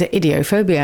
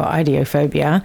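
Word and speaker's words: ideophobia: This week's phobia is idiophobia, and that's spelled I-D-E-O, and then phobia, P-H-O-B-I-A, or idiophobia.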